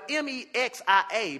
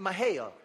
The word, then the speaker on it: Mexia: It was spelled M E X I A, but they pronounced it Mahia. I don't I don't know how you get Mahia out of an X.